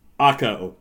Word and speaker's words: Ukko: The Buffalo Sabres just took Ukko Pekka Luukkonen, I think is how you pronounce it. – Bucko? – Ukko. U-K-K-O.